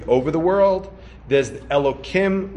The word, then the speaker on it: Elokim: Vayomar, and he says, well, the way we would read this is right, if you were the way we would pronounce it is Hashem Elokim. Right? When we read Navi, when we have two names of Hashem spelled like that, we would pronounce the first one as Adnas, as Adoshem, and the second one, which if it was alone, we would pronounce it as Adoshem. We pronounce it as Elokim.